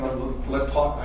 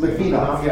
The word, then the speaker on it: Litvinov: Some, but he wasn't in Litvinov, I can't pronounce it. – Litvinov.